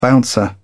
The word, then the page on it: bouncer: British English